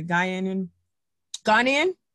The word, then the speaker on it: Ghanaian: She was born and raised in London, England, with a Ghanaian? – Correct. – I want to make sure I say it right.